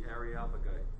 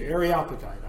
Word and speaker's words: Areopagite: The Areopagite. – Thank you, Areopagite. I was putting the emphasis on the wrong syllable.